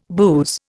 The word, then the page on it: booze: Indian